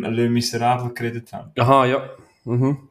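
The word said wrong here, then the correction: Les Misérables: Vom 2000, haben sie jetzt schön ausgesprochen? Le Miserable. – Les, Les Miserables.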